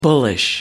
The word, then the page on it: bullish: American English